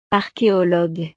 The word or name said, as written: archeologue